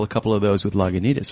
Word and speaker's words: Lagunitas: One of them is you just pronounced Lagunitas right. Really, think about that for a moment, you know, because when we first began, you know, it's like I lived in the town of Lagunitas, and I knew that most people in the towns around us didn't pronounce Lagunitas. It was Lagunitas and Lagunitas and all sorts of things.